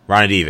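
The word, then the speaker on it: Ranadivé: Ramadov. Like that, I mean, that's like Redeemish or whatever, I can't pronounce the last name. – Ronadive, there we go.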